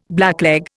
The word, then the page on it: blackleg: Indian